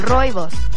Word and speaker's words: rooibos: Es un arbusto que se llama rooibos. Se escribe R-O-O-I-B de burro, O-S, rooibos.